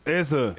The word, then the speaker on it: Eze: ¿Cómo se pronuncia ese o es? A ver. Es. – What's your full name? Aberici Eze. – Eze. – Ese. – Es Eberici ese. – Así lo dice él mismo.